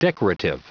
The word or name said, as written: decorative